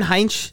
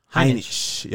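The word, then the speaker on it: Heinisch: Now, Ian Heinch, how do we say his name? Heininch? – Heininch. – Yep.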